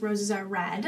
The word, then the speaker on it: Roses Are Read: It's actually pronounced Roses Are Red.